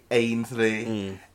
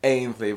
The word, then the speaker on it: Ainsley: Jamaicans can't pronounce my name properly. Even like my nan can't, like, can never. So instead of my name is Ainsley, Ainsley, but because they can't deal with vowels, I thought they put an H in front of it.